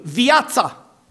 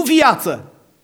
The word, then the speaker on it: viața: Și îmi place așa de mult cum evanghelistul Ioan, vă rog să verificați, pune accentul viața, nu viață.